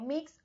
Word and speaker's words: Mx: Y en vez de ser un mister o miss, son mx, pronunciándolo como mix o mods.